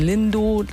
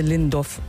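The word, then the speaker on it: Lindow: Schlimmer ist bei Endungen hier in Brandenburg, die auf OW sind, wie Lindo. Manche sagen dann fremde Lindorf.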